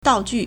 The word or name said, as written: dao4ju4